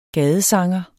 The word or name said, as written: gadesanger